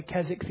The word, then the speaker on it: Keswick: It's spelled Keswick. It's not pronounced that way, Keswick theology.